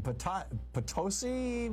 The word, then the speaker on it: Potosi: Jason in Potosi, Missouri.